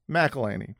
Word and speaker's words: McElhenney: Look at the eyes of Rob McElhenney. How do you pronounce McElhenney. – It? McElhenney.